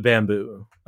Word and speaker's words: Bambu: And indeed, the top pick is the Bamboo. They spell it B-A-M-B-U, apparently.